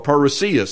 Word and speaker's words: parousia: It's the word coming in our English language, but it's the Greek word parousia, or parousia. Some pronounce it that way.